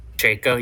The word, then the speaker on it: Pacheco: Isaiah Pacheco, yeah.